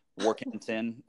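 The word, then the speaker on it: Warkenton: My last name's easiest to spell phonetically, so it's War like Civil War, Ken like Ken and Barbie, Tin like a tin can, War Kenton LLC.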